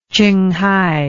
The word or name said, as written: chinghai